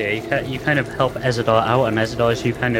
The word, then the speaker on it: Ezador: E Z A D O R.